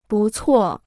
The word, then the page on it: 不错: bù cuò